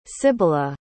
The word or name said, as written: sibyla